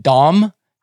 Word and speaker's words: Dam: Dam is, I think, actually pronounced Dom, Thomas Dom, but I like saying Dam better.